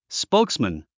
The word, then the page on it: spokesman: spóuksmən; スポークスマン